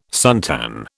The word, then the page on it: suntan: British English